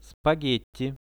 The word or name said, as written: spagetti